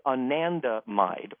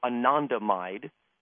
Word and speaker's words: anandamide: One of them is called anandamide, or anandamide, depending on how you pronounce it.